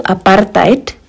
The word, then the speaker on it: apartheid: Y pronuncio apartheid porque es un concepto en afrikaans, que es otro de los 11 idiomas oficiales en Sudáfrica.